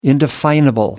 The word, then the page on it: indefinable: American English